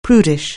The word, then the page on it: prudish: anglais